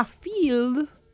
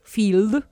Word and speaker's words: Feeld: Y después hay una que se llama Field, que se escribe F-E-E-L-D, Field, que sirve para machear solteros con parejas para actividades que pueden ser de muy variadas características y porte.